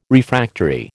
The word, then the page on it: Refractory: American English